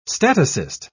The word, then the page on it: statocyst: British English